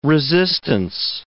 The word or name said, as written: resistance